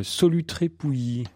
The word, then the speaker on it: Solutré-Pouilly: Blandine qui euh, habite, alors j'ai mal prononcé tout à l'heure, je suis confus, Solutré-Pouilly.